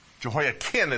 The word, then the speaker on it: Jehoiakim: Jehoiakim is how it's pronounced, which makes it even more complicated.